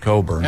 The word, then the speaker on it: Cockburn: Cockburn, as I used to say. – It's spelled kind of that way. It is spelled Cockburn, but it's pronounced Cockburn.